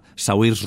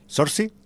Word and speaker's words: Saoirse: En fin, es una película donde la intérprete principal es la Saoirse Ronan. – Vale. ¿Sorsi? – Saoirse Ronan, sí, sería más...